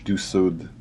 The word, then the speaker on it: du Sud: And like, I think I texted you the other day, is it Da Sud? Is that how you say it? – You say it with more of a French, more of a French Du Sud, I guess.